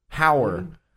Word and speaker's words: Heuer: Is that pronounced Heuer? – Heuer.